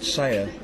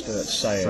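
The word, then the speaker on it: Sella: Mostly, there's a place, it's about 15 minutes, 20 minutes drive from here called Sayer. – Oh, yeah. – It's actually spelled S-E-double-L-A, but it's pronounced Sayer.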